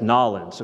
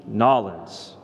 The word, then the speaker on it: Nawlins: You didn't say it right. It's Nawlins, okay? So what you need to do is N-A-W-L-E-N-S, the Big Easy, Nawlins.